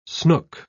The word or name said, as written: snook